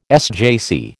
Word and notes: SJC: American English

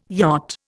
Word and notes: yacht: Indian

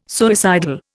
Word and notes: suicidal: Indian

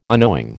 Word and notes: unknowing: American English

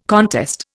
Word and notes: contest: Indian

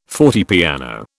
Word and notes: fortepiano: British English

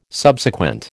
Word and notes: Subsequent: American English

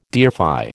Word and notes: deify: American English

